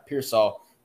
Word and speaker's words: Pearsall: They have They are like returning, I believe, Ricky Pearsall. Is that how you pronounce the last name? That's what we're doing. Yeah. – Pearsall.